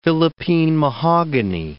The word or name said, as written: Philippine mahogany